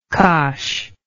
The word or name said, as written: cosh